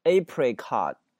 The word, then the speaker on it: apricot: 杏这个水果呢叫 apricot 啊，叫杏啊，这个水果 apricot，注意它的发音，这个 t 在结尾不用发出来啊，直接是 apricot， OK 啊 apricot。